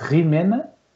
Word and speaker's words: Ximena: Gostaria de saber se a Ximena tem razão. É o nome da senhora, ainda não sei pronunciar devidamente. Lê-se Rimena. Na Colômbia o X não tem relevância fonética, é tipo uma, lesta, uma letra ostra, ostra, ostra, coisa, ou coisa, exato, ou uma cena assim.